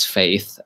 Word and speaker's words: F8: VR Oculus just had its F8 conference, which is supposed to pronounce faith, and that is really horrible.